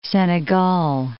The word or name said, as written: Senegal